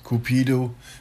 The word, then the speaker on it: Cupid: Cupido wird im Amerikanischen Cupid ausgesprochen und ist ein anderer Name für den Liebesgott Amor.